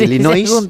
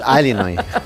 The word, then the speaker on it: Illinois: digan por qué dicen Illinois. Cosas nuestras, pero sí es de Illinois. – Es un estado de Estados Unidos que se pronuncia así. Es sí, Illinois, pero es Illinois.